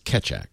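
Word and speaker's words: Keccak: K-E-C-C-A-K. K-E-C-C-A-K. – The press release says it's Ketchak. – Or Ketchak. And that's Ketchak. That's what I've seen is catch, C-A-T-C-H, phonetically again, C-A-T-C-H, and then A-C-K, Ketchak, which looks good enough to me.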